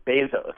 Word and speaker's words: Bezos: It's the name of his father, who's from Cuba, and they pronounce it Bezos.